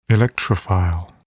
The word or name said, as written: electrophile